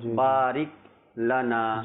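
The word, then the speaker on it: বারিক লানা (بارك لنا): সেটা হলো অনেকে এভাবে পড়ে বারিক বারিক লানা বারিক লানা বারিক, অর্থাৎ রিকে, হ্যাঁ রিপিট চলে আসে, উচ্চারণটা ভুল হয়ে যায় তখনই। আর কিন্তু সঠিক উচ্চারণ তো ওটা ছেড়ে দেওয়া যাবে না, বারিক লানা।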